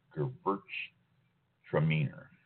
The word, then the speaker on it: Gewürztraminer: It is spelled G-E-W-U with the two little dots above it. U-R-Z-T-R-A-M-I-N-E-R. Gewürztraminer.